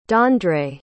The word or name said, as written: dandre